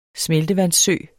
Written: smeltevandssø

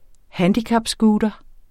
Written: handicapscooter

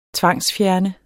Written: tvangsfjerne

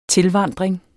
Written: tilvandring

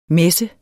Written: messen

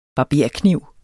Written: barberkniv

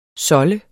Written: SOLDEDES

